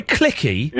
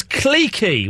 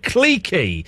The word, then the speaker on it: cliquey: It was cliquey. We are not American. We pronounce the word cliquey. – Cliquey. – C-L-I-Q-U... Then maybe there's an E and there might be a Y. I'm not sure. It's pronounced cliquey. There were cliques of people. There were not cliques. It was not cliquey.